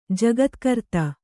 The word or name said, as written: ಜಗತ್ಕರ್ತ